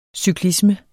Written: cyklisme

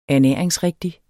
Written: ernæringsrigtig

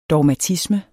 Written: dogmatisme

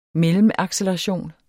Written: mellemacceleration

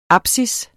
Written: apsis

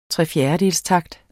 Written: trefjerdedelstakt

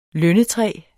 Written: lønnetræ

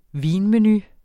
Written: vinmenu

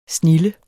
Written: snilde

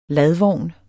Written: LADVOGNE

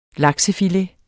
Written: laksefilet

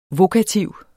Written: vokativ

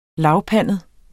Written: lavpandet

